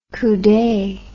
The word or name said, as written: coude